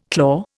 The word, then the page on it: claw: Indian